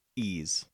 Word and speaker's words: Ys: Is it Yeese or Ease? – Ease. – It is Ease? Okay. – Like the letter E.